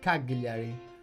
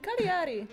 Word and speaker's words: Cagliari: Che bello come pronunciano Cagliari gli stranieri. Cagliari, you said Cagliari. – I did? – Yeah, maybe. – No! – No, try again. – Cagliari. – Brava.